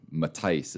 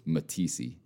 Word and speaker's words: Matice: Yeah, so I'm, Matice is my name, and the worst one I ever had was Matisse, and it's like, ooh, that's bad.